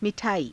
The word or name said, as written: mi_thaaii